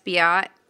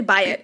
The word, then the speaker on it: Byatt: Biot. Is that how you pronounce her last name? I'm not sure. – It's actually Byatt. A.S. Byatt.